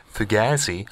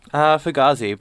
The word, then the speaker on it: Fugazi: And we're having a bit of a turnover with Fugazi. I hope I pronounced that correctly. Did I? – Fugazi, but like Fugazi. Not too far off.